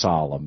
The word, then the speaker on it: Salem: And Salem International, it's pronounced Solemn, but it's spelled like Salem.